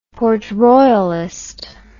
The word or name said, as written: Port-Royalist